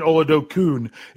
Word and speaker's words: Oladokun: Yeah, and I keep saying it right because I keep saying Oladokun because it's UN, but it's not. So it's the short U.